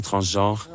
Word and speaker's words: transgenres: Transgères, c'est ça que tu as dit? – Transgères, les transgenres. – Ouais, transgenres.